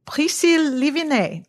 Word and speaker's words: Priscille Livenais: I am so pleased to introduce Priscille Livenais.